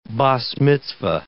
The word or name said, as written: bas mitzvah